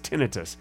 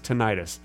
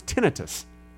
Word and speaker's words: tinnitus: And it is pronounced tinnitus. It's not pronounced tinnitus, no matter what you think. – Well, tinnitus sounds better. – Tinnitus sounds better, but it's pronounced tinnitus.